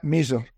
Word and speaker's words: Misr: Yeah, Mizr. Usually, if you spell it English, it's usually M-I-S-R.